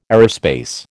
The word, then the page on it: aerospace: American English